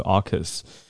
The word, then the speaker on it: AUKUS: 嗯我们看到的是 AUKUS，澳洲、英国、美国联合的联盟 AUKUS，读音是 AUKUS。